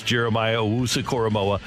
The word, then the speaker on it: Jeremiah Owusu-Koramoah: His nickname is J-O-K, but it's Jeremiah Owusu-Koramoah.